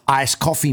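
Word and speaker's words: Ayscoughfee: This is it's spelled AYS and then cough, COUGH, fee. It's pronounced Ice Coffee Museum.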